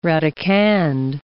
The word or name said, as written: radicand